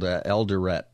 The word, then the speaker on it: Eldoret: It's on the outskirts of a town called Eldoret, I don't know if that, I'm saying it right.